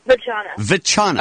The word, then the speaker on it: Vachana: Sorry, it's Machana. – Machana? – Vachana. – Oh, no. – You mean like vagina? – No, more like people always think it's vagina.